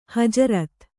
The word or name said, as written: ಹಜರತು